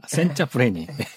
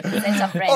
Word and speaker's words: Senza freni: 첫 번째 곡이 제가 발음이 맞는 분인데 센제나 프레니. – Senza freni. – Senza freni. – Correct. Senza freni.